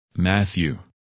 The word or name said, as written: Matthew